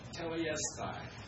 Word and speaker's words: tetelestai: You tell me if I'm even coming close to pronouncing. You pronounce it. Tetelestai? – I anglicize it quite a bit. Tetelestai? – I'll accept that.